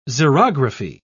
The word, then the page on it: xerography: British English